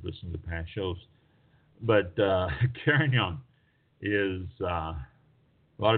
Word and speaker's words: Carignan: Carignan.